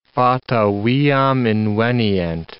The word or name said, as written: Fata viam invenient